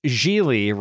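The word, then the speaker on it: Gigli: And that's Geely rhymes with really.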